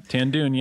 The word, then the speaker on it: Tan Dun: Tan Dun, yeah.